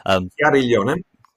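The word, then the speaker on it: Chiariglione: Now, I couldn't sit down with you, and not mention the legend that is Leonardo Chiaglione. Apologies for that pronunciation. – Chiaglione. – Thank you.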